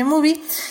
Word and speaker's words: MUBI: Estamos hablando de Shiva Baby, esta película que está en la plataforma de Movie, M U B I, así se escribe Movie, que acuérdense que tienen una semana gratis.